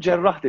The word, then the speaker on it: cerrah: Hatta o yüzden bize doktorları cerrah deniyor. Yaran anlamında, o işi yapan anlamında. E, şeddelenerek okunuyor. Mesela hammal gibi, kasap gibi, bakkal gibi.